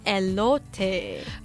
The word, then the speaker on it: elote: elote。 – 发音正确一点是 elote。